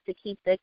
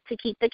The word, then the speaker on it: Tokeitha: Again, I am the best-selling author, Takeitha K. Wilson. My name is spelled T-O-K-E-I-T-H-A. You can also find me on Instagram, Twitter, Facebook at Takeitha K.